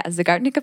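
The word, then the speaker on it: Zeigarnik: I know we've talked about these kind of like open loop effects and the Zagartnik, am I saying it right? – Yeah, Zagartnik effect.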